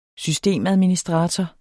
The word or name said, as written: systemadministrator